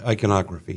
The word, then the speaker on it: iconography: Also, the iconotry, is that how you say it? – Mm-hmm. – Iconography, yeah.